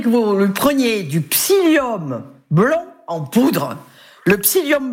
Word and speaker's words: psyllium: C'est psyllium, P-S-Y, psy, oui. – Comme le psy, deux L-I-U-M, psyllium blanc en poudre, j'insiste, en poudre.